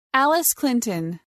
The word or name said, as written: Alice Clinton